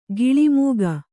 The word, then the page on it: ಗಿಳಿಮೂಗ: giḷi mūga